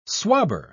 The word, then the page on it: swabber: British English